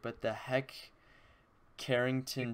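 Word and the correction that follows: Hec Crighton: Trophy. – Heck Crichton, yeah. – Heck Crichton Trophy.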